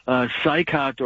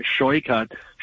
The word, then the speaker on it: Saikat: And this DSA completely just out of his mind AOC type of socialist Marxist Saikat, or he pronounces it Shoykat Chakrabarti.